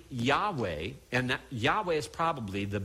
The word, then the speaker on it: Yahweh: would agree that Yahweh is the correct pronunciation of the title.